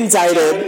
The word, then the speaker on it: indicted: Indicted. – I'm with you people. – Is he indicted? – There's a C in it. – It's a silent C.